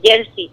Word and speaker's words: jersey: Bueno, pues jersey se acomoda y se pronuncia con pronunciación española en el resto de España. Pero nosotros lo pronunciamos jersey. Y esto es lo que dice Bartolo, que es súper curioso, porque en realidad nosotros lo estamos pronunciando con su pronunciación original.